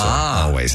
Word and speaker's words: Alvvays: By the way, the reason I asked if that's how you pronounce their name, they spell their name A-L-V-V-A-Y-S. So I guess the two V's together make a W. So always.